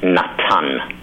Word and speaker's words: Natan: In Hebrew, it's Natan. Like the name Nathan, in Hebrew, it's Natan. And you would spell it N-A-T-A-N.